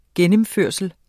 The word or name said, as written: gennemførsel